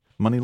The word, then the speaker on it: Muni: Money Long, spelled M-U-N-I, like the San Francisco Public Transportation Authority, Muni, but pronounced money.